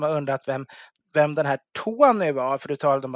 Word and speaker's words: Tawny: Men det är ju alltså Tony, T-A-W-N-Y. Så man ska inte söka på T-O-N-Y på Systembolaget, utan T-A-W-N-Y. Det är väl ett efternamn, Tony, antar jag. Men det uttalas, jag vet inte om det är exakt likadant eller bara väldigt snarlikt.